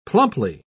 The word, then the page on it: plumply: British English